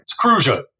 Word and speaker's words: Cruzan: Some Cruzan rum, no less. – Yeah, Cruzan rum. Cruza. Yeah, there's another pronunciation for you. You hear that? Cruzan. – Oh, right. – It's Cruza.